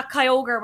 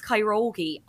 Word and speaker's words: Kyogre: When I was listening to this and you were saying, oh, yeah, Kyogre, whatever, blah, blah, blah. I was like, oh, my God, that's so embarrassing that they're pronouncing it wrong. It's Kyrogi.